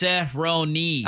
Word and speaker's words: Cerrone: By the way, somebody said his last name is pronounced Cerrone. – I know it's Cerrone. I just don't call him Cerrone.